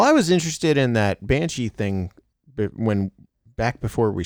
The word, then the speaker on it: Banshee: I've heard of that one, and I can pronounce it Banshee.